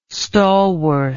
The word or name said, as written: stalworth